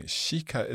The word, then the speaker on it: kika: Kika, is that like to look? – Yeah, so it's another word for it. So, and kika, pronounced like sh, because I, soft vowel.